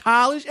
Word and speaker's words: Hollis: it's oh, it's pronounced Hollis.